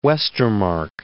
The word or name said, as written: Westermarck